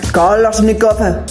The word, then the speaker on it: Kalashnikov: Soy incapaz de pronunciar Kalashnikov. A ver, el fusil de asalto soviético. Para poderlo decir, tengo que poner entonación como un ruso. Kalashnikov. ¿Veis? Ahora sí.